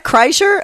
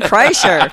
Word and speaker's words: Kreischer: And it's Bert and Leanne Kreshner. And that's how you say it, right? – Kreshner. Doesn't matter. – What? Kreischer? Oh, yeah. – Okay. It's Bert and Leanne Kreischer.